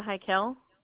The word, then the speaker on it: Hiykel: Is Andrea Heikel. – Can you spell that last name for me? – Sure. H-I-Y-K-E-L. – H-I-Y-A-E-L.